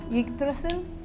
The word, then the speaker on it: Yggdrasil: Yggdrasil? – Yeah, that.